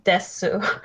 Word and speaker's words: desu: But mainly just to get the sounds, and that really helped my pronunciation, and that's literally where I first learned to stop saying desu. Oh, okay, it just ends after the S sound.